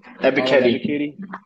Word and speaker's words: Ebiketie: Ebiketie.